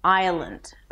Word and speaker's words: Island: Island. Of course, there is no S here.